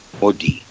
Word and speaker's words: Modì: Detto alla francese, Modì.